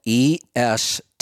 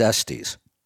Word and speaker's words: Estes: Well, the reason I'm asking is because I got an email from somebody who works there, and he says, stop saying Estes, it's Estes.